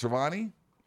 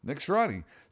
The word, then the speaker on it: Sirianni: And so he fired, his name's pronounced Sirianni? – Yeah. – He fired Nick Sirianni. – Nick Sirianni.